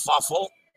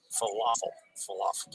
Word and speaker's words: falafel: So, schnitzel and fuffle are the other popular dishes. Falafel.